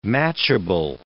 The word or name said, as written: matchable